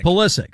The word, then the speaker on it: Pulisic: I've heard everything from Pulisic to Pulisic. – It's Pulisic. – It's Pulisic. Yeah. – It's Pulisic. – Pulisic. Pulisic. – No. – Pulisic. Pulisic. – Pulisic.